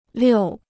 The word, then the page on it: 六: liù